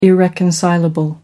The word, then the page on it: irreconcilable: American English